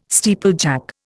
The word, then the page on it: steeplejack: Indian